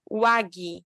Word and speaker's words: WAGI: Você vai usar um negocinho que chama WAG. Se escreve W-A-G-I. WAG,